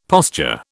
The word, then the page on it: posture: British English